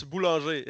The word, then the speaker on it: Boulanger: Oh, it's Boulanger. It's just the French word for baker.